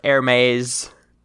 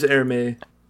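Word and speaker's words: Ermes: Also, I'm receiving on the spot editorialization that the name of the stand user of Kiss is pronounced Hermes. – It is Hermes. Hermes or Hermes? – Hermes. – Okay. – With a Z sound at the end.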